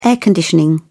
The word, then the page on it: air conditioning: British English